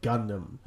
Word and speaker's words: Gundam: I don't wanna yuck your yum, but it's pronounced Gundam.